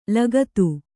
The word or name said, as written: ಲಗತು